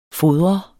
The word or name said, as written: fodre